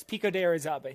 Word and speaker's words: Pico de Orizaba: But what we're here to talk about today is we are here to talk about the infinity loop around Pico de Orizaba. Did I pronounce that right? – You got really close. Pico de Orizaba, yeah.